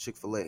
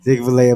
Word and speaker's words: Chick-fil-A: Chick-fil-A, a play on the American English pronunciation of filet.